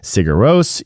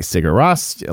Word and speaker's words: Sigur Rós: Those of us who are not Icelandic, you hear people say Sigur Rós, you hear people say Sigur Rós, like Ross from Friends.